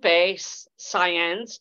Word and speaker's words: Saenz: But today, I want to welcome Lupe Saenz. Did I say your last name correctly? – Yes, it's like saying science.